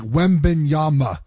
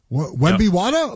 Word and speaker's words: Wembanyama: Like, my daughter's trying to pronounce his name. She can't do it right. like, Wembanyama. Wemby Wada? I'm like, no, honey.